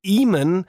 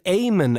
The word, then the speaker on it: Eamon: Also vielleicht ist es die Befürchtung, dass ich nicht weiß, dass der Künstler nicht Eamon, sondern Eamon ausgesprochen wird. Also E-Amon geschrieben. Man würde jetzt so aus dem Bauch heraus sagen, ja, das ist der Eamon. Nein, das ist natürlich der Eamon. Also ist das damit gemeint?